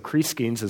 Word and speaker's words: Crescens: Seems to be the way that name is pronounced. Not a name I'm real familiar with, but I looked at it back in the Greek, and it looked like long E sounds there. So Crescens is the way I'm going to say it.